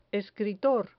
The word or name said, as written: escritor